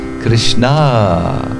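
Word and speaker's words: Krishnā: This lesson, the great devotee Draupadi, who was so close to Krishna that people called her Krishna with long A.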